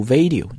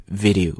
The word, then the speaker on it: video: 第一个难点在于这个 v，第二个难点呢在于这个短音的 i 啊，千万不要把它读成了 a，很多人读 video， no it's video， v v v，很快的一个力量， video， video， very good。